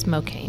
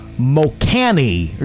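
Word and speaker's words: Mokane: No, it's kind of like Spokane, but it's Mocaine. – Mocaine. Okay. In Mocaine, Missouri, I was going to go Mocani or something, because I always take the path of most resistance when it comes to pronouncing city names.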